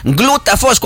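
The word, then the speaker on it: Glutaphos: Eso iba a preguntarle, las edades para poder consumir Glutafos. Cuando usted vaya a la farmacia, cuando usted busque Glutafos, se escribe P-H-O-S, FOS, ¿verdad? – Sí, pero Glutafos se escribe POS. Pero se pronuncia FOS.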